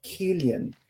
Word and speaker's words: Cillian: Ganhou o Cillian Murphy com Oppenheimer. Ele estava ah, indo. Querido, eu amei a pronúncia do nome dele. Deixa eu te corrigir. É Killian. – Killian, Killian Murphy. perdão.